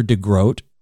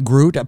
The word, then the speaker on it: DeGroot: It looks like Groot. People mistake my name all the time with the double O, so I hope you'll forgive me.